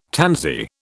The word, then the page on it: tansy: British English